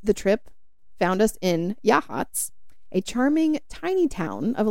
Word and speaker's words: Yachats: Also a town that Danielle and I absolutely butchered the name, pronouncing it Yakats, until one of the retreat goers informed us of how to actually say it.